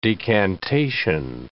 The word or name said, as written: decantation